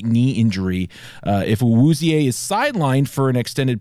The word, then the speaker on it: Awuzie: Cincinnati lost its best cornerback when Chidobe Awuzie, is that how you say his name? I don't even know how to say his name. Well, he departed the first half. – Awuzie, actually, that sounds right. Now that's triggering something in my head. Awuzie sounds right.